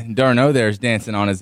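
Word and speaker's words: d'Arnaud: I said that correctly, Theo? – Yeah, Darno. – Darno. – Yeah. – Travis Darno, who I called Darnod yesterday, or D-R-Nod.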